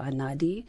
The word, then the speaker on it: Einaudi: Einaudi Ludovico. – Yes. – Einaudi Ludovico. – Yes. – Or Ludovico Einaudi. – Yeah, Ludovico Einaudi.